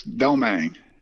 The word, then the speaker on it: Domingues: Your last name is Domingues, but how do they pronounce it in the bayou? – It's Domingues. – Domingues. – Like lemon meringue.